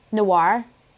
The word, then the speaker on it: noir: No, noir. Like Pinot Noir.